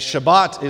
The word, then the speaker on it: Shabbat: It's an ancient transliterated Hebrew word, and Shabbat is the way that it's pronounced, and it actually means literally rest.